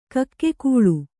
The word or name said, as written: ಕಿಗ್ಗಳ